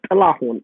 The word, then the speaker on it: Tilahun: But the truth is, so Tilahun is my Ethiopian name. That's my birth name, T-I-L-A-H-U-N.